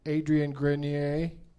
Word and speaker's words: Adrian Grenier: Adrian Grenier.